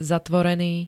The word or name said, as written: zatvorený